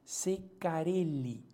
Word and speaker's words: Ceccarelli: Sé, yo estoy pronunciando che como se pronuncia en italiano, no es Ceccarelli, ¿no?